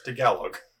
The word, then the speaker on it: Tagalog: For those of you Filipino people, I'm sorry that I just butchered the name of your language. It's Tagalog, but yeah, but I find it funnier that it's the chocolate covered peanut butter cookie from the Girl Scouts.